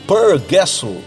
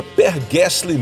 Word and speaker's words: Per Gessle: O Per Gessle então, aliás, é, fazendo aqui um parênteses, é assim mesmo que se pronuncia o nome dele. Eu já vi muitos jornalistas pronunciando o seu nome como se ele fosse inglês, tipo Per Gessle. Mas na verdade, a gente fala como se, como se escreve, é Per Gessle mesmo, a maneira correta de pronunciar o seu nome.